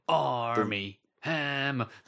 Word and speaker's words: Armie Hammer: Arnie Hammer is in that, and he is playing Armie Hammer. That's how every time you say his name, that's what I hear.